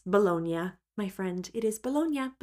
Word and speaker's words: Bologna: So I've always said Bologna, but I realize now it sounds kind of funny if you haven't heard it before. So that is how you say the word Bologna.